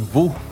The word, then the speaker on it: Vault: Vu. Vu. Se escreve V-A-U. V-A-U-T. U-T, mas se pronuncia Vu Produções, né?